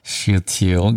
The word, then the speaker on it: Shealtiel: Shealtiel, governor of Judah, and to Joshua, the son of Jehozadak, the high priest.